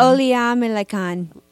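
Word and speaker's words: Olamilekan: L-E-K-A-N. Yeah. How do you pronounce that? How's your pronunciation on that one? – Oleamelekan. – Oleamelekan. Oh, yeah.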